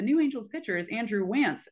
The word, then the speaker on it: Wantz: And then, you know, I looked at his baseball reference page and it literally says Wantz rhymes with pants.